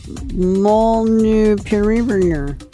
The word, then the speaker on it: Molnupiravir: Molnupiravir.